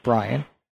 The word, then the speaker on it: Brien: People were always asking, name of your show is Ask Brian. You're not Brian. And it's spelled B-R-I-E-N.